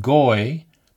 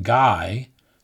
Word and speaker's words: goy: The word goy, popularly pronounced guy, simply means a Gentile, a non-Jew.